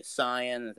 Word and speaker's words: Saiyan: I just want to say Saiyan. That is the correct pronunciation.